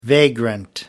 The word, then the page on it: vagrant: American English